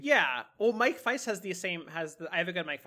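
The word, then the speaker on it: Faist: And he said, Mike faced Wonka from West Side Story and the upcoming Challengers. And I think it's, is it Faced? – Feist. – I don't know how you pronounce it. Mike Feist.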